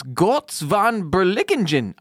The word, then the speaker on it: Götz von Berlichingen: Today's stuff to Wikipedia when you're high is Götz von Berlichingen. I have no idea how to pronounce it, so that's what we're going with. – I think that was pretty spot on.